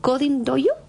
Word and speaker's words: Coding Dojo: Y así nace Coding Dojo, ¿así se pronuncia? – Sí, así nace.